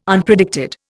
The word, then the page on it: Unpredicted: Indian